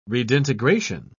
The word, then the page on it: redintegration: British English